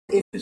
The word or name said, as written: ifis